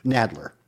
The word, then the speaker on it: Nadler: You know, Jerry Sadler's, Sadler is the, am I getting his name right? Nadler. Nadler is the chair of the House Judiciary Committee, and he used to be like, at least twice the size as he is now.